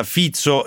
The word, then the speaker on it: Fico: Le 17:36 minuti, qualche sms sul premier slovacco che ci dite tra l'altro che si pronuncia Fizzo e non Fico come abbiamo detto noi, però insomma ci è scappata la pronuncia all'italiana, Robert Fico.